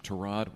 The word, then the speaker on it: Tyrod: He insists on being called Tyrod. – Did they change that? I heard it starting, the people were pronouncing it differently. – During Hard Knocks, there was an episode almost devoted to how Tyrod Taylor wants his name pronounced. And he told everybody, he said, it's been Tyrod in my family my whole life, but everybody started calling me Tyrod when I came to the NFL, and he didn't.